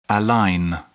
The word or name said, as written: align